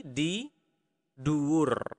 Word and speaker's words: di dur: Okay, so again, at is di, in is di dalam, on is di dur. Okay, so practice that and make sure to really pronounce the R clearly.